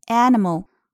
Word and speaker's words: animal: 注意 animal，那么英音里面呢，i 发的是短音 a，那么在美音里面，我们前面讲过有一个弱读，读成短音 a animal。